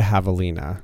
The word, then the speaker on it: javelina: What is a javelina? – That is correct. And that is an animal whose name begins with a J. – Mm-hmm. – For 300. – Soft J. – That's right.